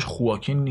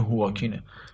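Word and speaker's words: Joaquin: خواکین فینیکس اینم بگم تلفظش خواکین نیست یه چیزی ما بین خواکین و هواکینه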